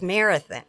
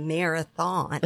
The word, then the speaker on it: Marathon: Well, I was told early on when I got my CASA in 2005, it's Marathon. We do not say Marathon. That's the race that we have in October.